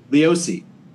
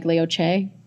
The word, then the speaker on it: Leosi: Leosi? Is that how you pronounce it? Leosi. Yeah. What's the origin of the name? – The origin is Italian, If we were really being traditional about that, then it would probably be something like Leoce.